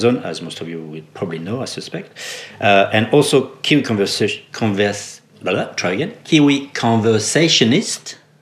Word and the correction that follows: conservationist: Is that how you say it? – Conservationist. – I mixed it up, didn't I? – You're allowed to.